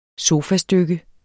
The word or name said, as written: sofastykke